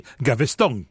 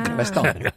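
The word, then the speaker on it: Gaveston: Il est gascon et donc j'imagine qu'on devait prononcer Gavestong. – Oui, ah. Gavestong.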